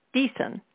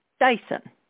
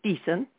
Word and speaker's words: Deason: My last name, Deason, is not as easy for people to know how to pronounce. I sometimes hear it as Dyson. A good way to remember how to pronounce Deason is to think about the letter it begins with and say it out loud.